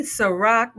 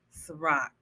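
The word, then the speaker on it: Cîroc: My homeboy called it IROC. Hey, you still got some on that IROC? You mean Ciroc, motherfucker? Ciroc? Oh, that's how you pronounce it? Yes, Ciroc.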